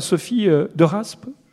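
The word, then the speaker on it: Sophie Deraspe: réalisé par Sophie de Rasp. Je l'ai prononcé correctement ? – Oui, c'est bien ça.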